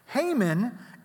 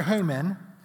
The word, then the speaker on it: Haman: Now, if you're Haman and you're the number two, who do you think the king's talking about, right? Right? Starts with an H, rhymes with Amen, right?